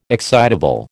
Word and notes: excitable: American English

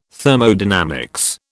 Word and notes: Thermodynamics: British English

